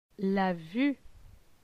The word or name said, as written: La vue